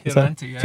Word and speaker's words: Fioravanti: Fioravanti, yeah, well pronounced. It's a hard one to...